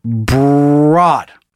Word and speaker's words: Broad: If you aren't familiar somehow with this real estate mogul, it's pronounced Broad, like road. Oh, sorry. – Broad. – Broad, not Broad, with a B. Like the lady, even though it's exactly how it's spelled.